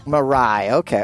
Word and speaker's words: Mirai: It was called the Miria, M-I-R-A-I. Miss Victoria, how would you say it? – Mirai. – Mirai, okay. Oh, like Mariah.